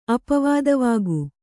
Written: ಅಪವಾದವಾಗು

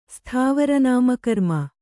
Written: ಸ್ಥಾವರನಾಮಕರ್ಮ

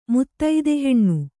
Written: ಮುತ್ತೈದೆಹೆಣ್ಣು

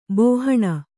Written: ಬಾಹಣ

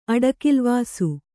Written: ಅಡಿಕಿಲ್ವಾಸು

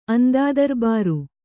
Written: ಅಂದಾದರ್ಬಾರು

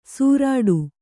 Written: ಸುರಟು

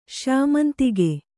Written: ಶ್ಯಾಮಂತಿಗೆ

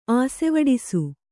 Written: ಆಸೆವಡಿಸು

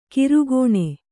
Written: ಕಿರುಕೋಣೆ

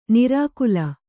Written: ನಾರಿಕೇಲಿ

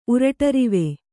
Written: ಉರಟರಿವೆ

